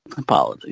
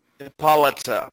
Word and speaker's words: Hippolyta: And Hippo- Hippolyta or however the fuck you say her name. – Hippolyta, yeah. – Hippolyta.